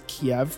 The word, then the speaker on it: Kiev: I grew up pretty fascinated by the Eastern Front in World War II and always read or heard the capital of Ukraine pronounced as Kiev, which is the Russian pronunciation. Kyiv is the Ukrainian pronunciation.